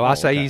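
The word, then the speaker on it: açaí: So acai is traditionally, it's a tart, like an oversized blueberry, and Brazilian. And that's where the pronunciation comes from. So A-C-A-I, acai, Brazilian.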